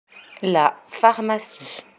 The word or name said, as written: la pharmacie